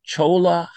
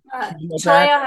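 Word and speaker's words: Cholla: So maybe you could talk about, is it pronounced Chola Heights on Chaya Heights? Yeah. I know you're not a local when you pronounce it.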